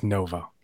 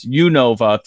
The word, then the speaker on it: Unova: Okay, we're getting Unova, so that's how the official pronunciation, according to our chat, is Unova.